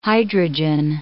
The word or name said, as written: hydrogen